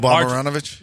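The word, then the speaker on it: Marjanović: Boban Marjanovic? – Yeah, Marjanovic.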